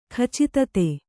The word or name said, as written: ಖಚಿತತೆ